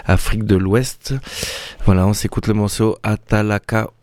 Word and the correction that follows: Atalaku: ou non, comme j'ai mal prononcé, pardon, excusez-moi, Atalaku.